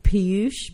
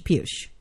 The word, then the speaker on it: Piyush: Piyush. Piyush. – Piyush? – Mm-hmm. Piyush. – Yeah. Rhymes with douche.